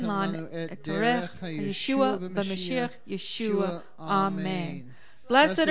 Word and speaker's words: Yeshua: So let's bless the Messiah, whose name is Yeshua. Let's all say His name. – Yeshua.